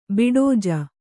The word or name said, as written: ಬಡೌಜ